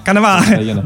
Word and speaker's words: Cannavale: Cannavale. Genau.